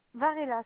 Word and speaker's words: Varela: c'est Varela. Varela, ça s'écrit VARELA, Lopez avec un S, Fernanda Maria.